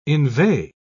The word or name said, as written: inveigh